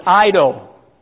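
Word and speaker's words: Ido (εἴδω): Now, believe it or not, that's in Greek, English Greek, it would be E-I-D-O. But the E-I is a long I, so it's Ido.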